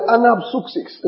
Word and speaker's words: anapsuxis: It's the word anapsuxis in the Greek. I'll spell it. A-N-A-P-S-U-X-I-S.